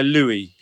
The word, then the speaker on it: Louis: Some people say Louis. I go by Louis, usually. – Louis, yeah, okay. – The French spelling. – Yes. – And the French pronunciation.